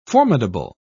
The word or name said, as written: formidable